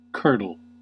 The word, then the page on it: curdle: American English